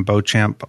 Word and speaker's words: Beauchamp: I am so excited to have Adam Beauchamp on the phone today and uh, talking about RTI and stuff at his school.